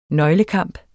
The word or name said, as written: nøglekamp